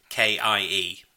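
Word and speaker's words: koekje: It's I presume you pronounce it Koike? It's Koekie. And it's defined as akin to cake.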